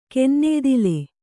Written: ಕೆನ್ನೆಯ್ದಿಲೆ

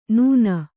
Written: ನೂನ

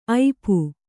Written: ಐಪು

